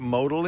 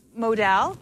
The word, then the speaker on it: modal: Do you know what modal is? – Modal. – Modal. Is that how you pronounce it? You knew. Not only did you know how to pronounce it.